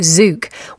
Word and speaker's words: Tzuke: Before that, Stay With Me Till Dawn from Judy Zook with a silent T, yes, spelt T-Z-U-K-E.